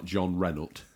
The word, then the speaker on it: Jean Reno: With Jean Reno. – Jean Reno? – Jean Reno, the man named after a car. – Or if you were pronouncing him properly, it would be something like John Renault.